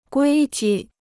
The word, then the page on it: 规矩: guī ju